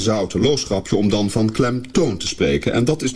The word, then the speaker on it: klemtoon: De klemtoon van de klemtoon is natuurlijk klemtoon en niet klemtoon. Het is immers de toon die de klem krijgt.